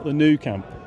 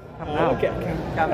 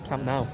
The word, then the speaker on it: Camp Nou: It's not the new Camp. – Camp Nou. – Absolutely. – Camp Nou. – Perfectly pronounced.